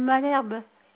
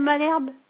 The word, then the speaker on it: Malherbe: Mon nom, c'est Madame Malherbe. – Ça s'écrit comment? – Monsieur Madame Malherbe.